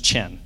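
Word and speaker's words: chen (חן): it means chen, or it's pronounced chen. C-H-E-N.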